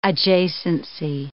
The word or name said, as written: adjacency